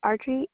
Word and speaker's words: Audrey: Audrey, A-U-D-R-E-Y.